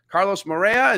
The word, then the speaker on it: Moreira: We got Carlos Morea.